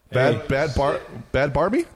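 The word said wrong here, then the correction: Bhad Bhabie: Bad Baby.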